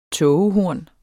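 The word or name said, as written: TÅGEHORNET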